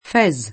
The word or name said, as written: Fez